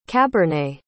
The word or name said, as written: cabernet